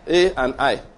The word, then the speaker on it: Ai: In AI, is it AI or I? How do you pronounce that now? A and I.